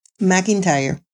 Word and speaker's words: McIntyre: McIntyre.